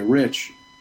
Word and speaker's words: Rich: So here's the I started ordering food using the name Rick because I found using the hard K on the end made people get the name right. So if I say Rich, usually they're like, you know, people are like, Chris, you know, or they say something completely unrelated, and I was, you know, like, Tim?